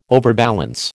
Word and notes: overbalance: American English

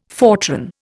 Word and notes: Fortran: Indian

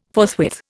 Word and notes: forthwith: Indian

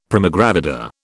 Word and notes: primigravida: British English

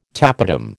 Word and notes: tapetum: American English

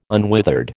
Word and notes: unwithered: American English

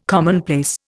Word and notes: commonplace: Indian

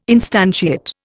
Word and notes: instantiate: Indian

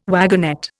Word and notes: wagonette: Indian

